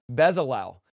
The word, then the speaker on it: Bezalel: Bezalel.